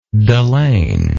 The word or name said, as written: delaine